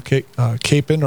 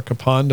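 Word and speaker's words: Kapaun: And then I really like Father Emil Capon, or Capon, depends upon if you use the German pronunciation or the Slovak pronunciation.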